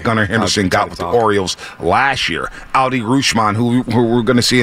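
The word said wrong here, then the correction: Adley Rutschman: Adley Rushman's the pronunciation.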